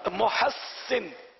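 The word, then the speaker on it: محسّن: حضرت محسن تلفظ صحیح نہیں ہے اسے. – حضرت محسن. – اب وہ یاد کروانے والے نے شاید محنت تو کی ہوگی لیکن جواب درست مان لیا جاتا ہے. محسن سین تشدید.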